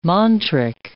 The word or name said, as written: mantric